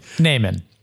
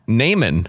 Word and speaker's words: Naaman: Naaman. Today we're talking about Naaman. – Naaman, not Haman.